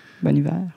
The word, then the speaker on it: Bon Iver: Bon hiver. Bon hiver, bon français. Euh, c'est comme ça qu'il prononce. voilà, bon hiver. Je me suis fait corriger. Donc, euh, euh, la chanson s'appelle Halloween.